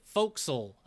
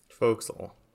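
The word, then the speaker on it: fo'c'sle: And because he says it with all the he spells it Foxel, FOCCSLE, which I'm pretty sure is how you pronounce that word that's written like forecastle or whatever. – Foxel.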